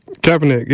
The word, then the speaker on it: Kaepernick: Kaepernick, yeah.